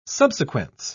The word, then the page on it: subsequence: British English